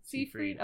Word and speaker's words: Seyfried: But Amanda Seyfried, is that how you say it? – Seyfried.